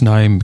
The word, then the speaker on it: KNIME: And that's Naim, K-N-I-M-E, silent K.